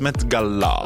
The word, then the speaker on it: Met Gala: Non, mais je crois que ça se prononce Met Gala.